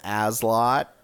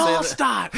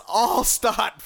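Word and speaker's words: Alstott: He'd bring the game over and he'd call him Azlot. It made me so mad because he wouldn't say the name right. – You're like, how do you it's say Allstott. That? – Yeah. – Fucking Allstott. – It's Allstott. Fuck you.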